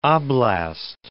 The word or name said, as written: oblast